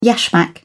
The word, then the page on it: yashmak: British English